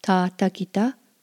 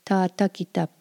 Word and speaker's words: tâtakita: So if you are Oto, you would introduce yourself as Ta Takita Pasuhara. Or if you are Pawnee and Oto, you would say Ta Takita Pari A Pasuhara.